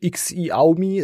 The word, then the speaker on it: Xiaomi: Es wird dieses, es wird Xiaomi ausgesprochen, nicht Xiaomi, es wird Schau. Wie Schau, yeah. wie Schaum und I, Xiaomi yeah. wird das ausgesprochen.